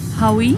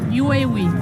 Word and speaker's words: Huawei: Hawaii? Hawaii? – Huawei. Huawei. Hawaii. Ok. Denne mobiltelefonen vi ofte kaller Huawei, skal egentlig uttales Huawei.